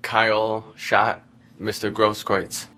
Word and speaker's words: Grosskreutz: Mr. How do you say his last name? – Grosskreutz. – Grosskreutz? – Good enough.